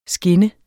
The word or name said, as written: skin